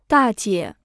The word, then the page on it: 大姐: dà jiě